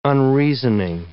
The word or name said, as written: unreasoning